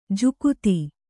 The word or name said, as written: ಜಕ್ಕಾತಿ